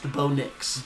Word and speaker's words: Bo Nix: And then No Bix, or correctly pronounced Bo Nix.